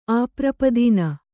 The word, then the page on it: ಅಪರೂಪದಿನ: aparūpadina